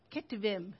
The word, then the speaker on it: Ketuvim: Ketuvim.